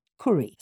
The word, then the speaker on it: Kuri: It's called Curry. Spell it Curry. K-U-R-I for iPhone and iPad.